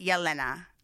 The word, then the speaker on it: Elena: So she pronounces it Yelena. – Yelena. – Yeah, but everybody else calls her Elena.